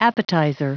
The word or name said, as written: appetizer